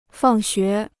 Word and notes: 放学: fàng xué

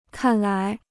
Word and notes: 看来: kàn lai

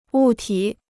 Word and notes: 物体: wù tǐ

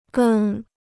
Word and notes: 跟: gēn

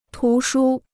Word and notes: 图书: tú shū

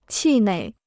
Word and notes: 气馁: qì něi

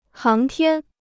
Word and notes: 航天: háng tiān

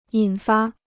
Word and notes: 引发: yǐn fā

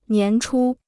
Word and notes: 年初: nián chū

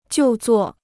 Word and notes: 就座: jiù zuò

